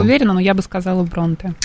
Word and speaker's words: Бронте: Эмили Бронте, я правильно? – Я бы сказала Бронте все же.